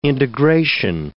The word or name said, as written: integration